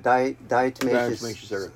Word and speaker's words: diatomaceous: Diatomaceous earth, which is is it a clay-like substance?